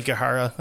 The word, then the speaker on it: Aokigahara: Whether it's something like the Teke Teke legend or the Korigara suicide. Aokigahara is how I would pronounce it.